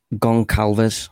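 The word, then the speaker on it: Gonçalves: Goncalves. Pedro Goncalves. He's spelt with a G-O-N, is it?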